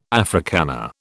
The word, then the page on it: Africana: British English